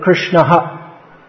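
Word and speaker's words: Kṛṣṇaḥ: So if it comes, generally if it comes at the end of a shloka or halfway through, then it's, just like we see the word Krishna with an H dot under at the end of it, Krishna ha, pronounced like that.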